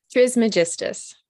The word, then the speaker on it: Trismegistus: it starts with P-R-I-S. How do you say that? – Trismegistus.